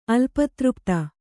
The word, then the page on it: ಅಲ್ಪತೃಪ್ತ: kannada